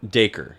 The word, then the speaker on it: Dacre: Dacre. – Yeah, I just had to look it up. I looked up an article that was, how do you pronounce it? And they said it's just like Acre.